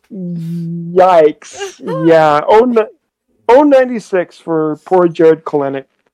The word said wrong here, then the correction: Kelenic: I think Kelenic. It's Kelenic.